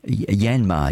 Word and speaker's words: Yanmar: It's actually got one of those uh, Yamna Yanmar, Yanmar.